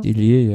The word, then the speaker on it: Dillier: Ähm, für BMC Racing ähm, Sylvain Delier habe ich das richtig ausgesprochen? Dillier? Dillier, ja. Dillier. Genau.